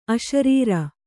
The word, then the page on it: ಅಶರೀರಿ: aśarīri